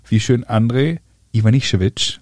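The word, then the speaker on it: Ivanišević: Der doofe Deutsche sagt ja Ivanisevic. Ja. Oder, oder noch schlimmer Ivanisevic. – Ivanisevic, das ist das Allerschlimmste aber Ivan, Ivan Nischewitsch.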